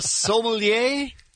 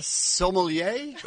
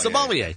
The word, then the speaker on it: sommelier: A koala sommelier. – Did I say that right? One more. – Koala sommelier. – That's better. – Sommelier? – Yes, sommelier.